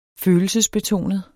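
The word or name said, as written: FØLELSESBETONET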